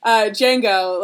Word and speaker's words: Django: How do I say it? – The D is silent. – Oh, okay. Django,